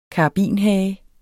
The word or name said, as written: karabinhage